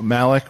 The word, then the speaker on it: Malik: and is that how you say it? – Malik, Revan, for sure.